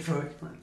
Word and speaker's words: Fraoch: It's spelt F-R-A-O-C-H. – So yeah. It's Clive, that's how you pronounce it. – I think it's Froch. – Is it Froch? – Yeah.